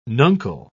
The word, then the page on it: nuncle: British English